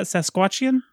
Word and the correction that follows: Saskatchewan: Regina, Saskatchewan. – Saskatchewan.